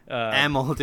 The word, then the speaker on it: Amell: You've mentioned, like, and we've, you know, seen videos of Stephen Email. – Amell, dude. His name is not pronounced Email.